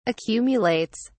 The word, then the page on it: accumulates: British English